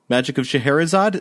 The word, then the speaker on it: Scheherazade: The Magic of Scheherazade.